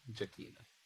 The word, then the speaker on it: Jekina: S-A-N-G-U-I. – Can you spell the first name again? – J-E-K-I-N-A. Jaquina.